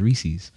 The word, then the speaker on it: Reese's: Yeah, it's not racist, it's Reese's. – I said Reese's. – Technically, it's Reese, but it's, I think it's possessive because it's a Reese's cup.